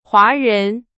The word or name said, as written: 华人